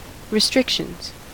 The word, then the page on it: restrictions: American English